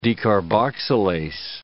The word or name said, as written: decarboxylase